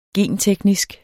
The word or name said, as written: genteknisk